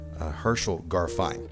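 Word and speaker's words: Herschel Garfein: I'll see you next time with composer, Grammy Award-winning composer and librettist, Herschel Garfine. And that's the way you pronounce his name.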